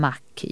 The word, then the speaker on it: Mach: Det uttalas ungefär som Mach i bensinmack.